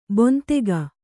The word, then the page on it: ಬೊಂತೆಗ: bontega